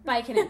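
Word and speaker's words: Byykkonen: Baikkanen.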